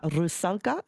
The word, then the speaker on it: Rusalka: He is here in Brisbane to conduct the opera Rusalka.